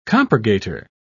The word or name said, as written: compurgator